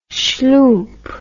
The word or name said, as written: schloomp